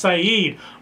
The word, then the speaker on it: Saeed: It looks like said, it's pronounced Saeed.